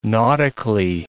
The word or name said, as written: nautically